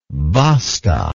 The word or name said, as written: basta